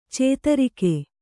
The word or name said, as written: ಚಿತ್ರಿಗ